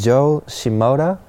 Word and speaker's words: Joe Shimoda: Yes, many people's been saying, so my name is Joe Shimoda, and many guys say Josh Imoda, which is like totally different.